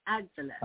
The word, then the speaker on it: Avila: Avila.